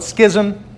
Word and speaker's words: Schism: It's sometimes called Schism. It's actually pronounced Schism, but the Great Schism happened in 1054.